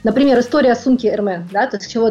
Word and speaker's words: Hermès: Ну, мы знаем, что на самом деле правильно Hermès. Hermès, how to pronounce Hermès.